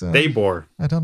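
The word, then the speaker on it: Daboll: Ich habe übrigens mit Sebastian Vollmer gesprochen, dessen Trainer war Brian und der den Namen Daybor ausgesprochen, was ich sensationell fand. Hab ich ihn nochmal nachgefragt, Sebastian, meinst du das echt? Der heißt Brian Daybor? Der meinte, ja, Daybor. So haben wir ihn genannt, so hat er sich selbst genannt.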